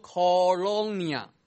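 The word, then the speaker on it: colonia (κολωνία): 但是呢，你看见它的希腊文读音读作 colonia， colonia 这个字后来英文的殖民地就从这个字而来。所以呢，中文的新译本把这个住房城就把它翻译为叫殖民地，而吕正中译本把这个 colonia 就把它翻译为叫退伍兵城。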